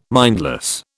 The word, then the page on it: mindless: British English